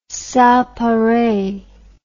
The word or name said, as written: sans pareil